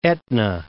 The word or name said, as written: Etna, Mount